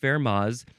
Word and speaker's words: Fermat's: And Fermat's looks like Fermat's, capital F-E-R-M-A-T, apostrophe S, but it says it's pronounced Fermat's, so I'm guessing that the T is silent. The T, no T sound.